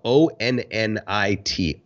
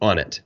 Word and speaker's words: Onnit: It's spelled O N N I T. Onnit. On it.